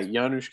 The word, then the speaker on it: Janusz: Yeah, Janusz Kaminski.